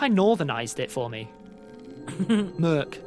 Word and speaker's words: Meerk: Is it Merc or Mirk? I thought Bryn keeps saying Mirk. – I'm pronouncing it incorrectly. – I mean, it's Indraconic and it's a transliteration. – Okay. – So we're doing our best. – I get the impression it's more Merc, isn't it? – Yeah, it's like Merc. A cobbledish, draconic-ish sound. I've spelt it M-E-E-R-K.